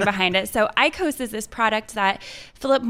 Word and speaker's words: IQOS: It's all about reduced-risk smoking and, quote, smoke-free products, which are, on the Philip Morris side, a product called IQOS. It's I-Q-O-S. And that is launching... – See, that's how you pronounce it.